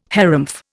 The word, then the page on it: harrumph: Indian